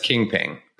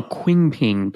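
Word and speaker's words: Qingping: I'm going to pronounce it as King Ping, but it's Q-I-N-G-P-I-N-G. – I would have gone Quing Ping, but okay, yeah.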